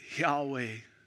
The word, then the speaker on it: Yahweh: Y-H-W-H, the name we would pronounce it, Yahweh.